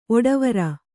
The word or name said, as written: ಒಡವಾರ